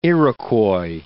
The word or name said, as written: Iroquois